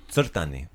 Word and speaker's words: crtani: Did you learn how to say the word cartoon in Croatian? – I did not. – Crtani. – Crtani. – Crtani. – Crtani. So with the TS, yeah? – Yeah. Crtati is to draw.